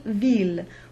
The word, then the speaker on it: ville: We talked a lot about the ILLE being the J glide or the yod, but we also mentioned the exceptions, mille, tranquille, ville, where the L is pronounced.